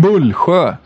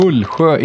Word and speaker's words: Mullsjö: Men jag blev lite sur på Patrik Westberg och här tycker jag att vi ska ha upprättelse för den fina orten Mullsjö. Den heter Mullsjö, ingenting annat. Den heter inte... Ja, han hade en betoning på det som var väldigt skev.